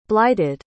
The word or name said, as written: blighted